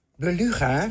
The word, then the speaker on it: beluga: Beluga spreek je uit als beluga.